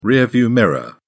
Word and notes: rearview mirror: British English

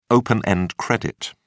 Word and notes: open-end credit: British English

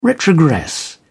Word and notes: retrogress: British English

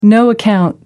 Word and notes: no-account: American English

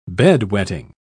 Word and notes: bed-wetting: British English

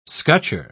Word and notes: scutcher: British English

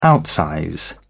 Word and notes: outsize: British English

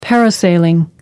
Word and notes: parasailing: American English

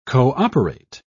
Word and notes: co-operate: British English

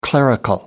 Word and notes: clerical: American English

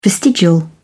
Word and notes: vestigial: British English

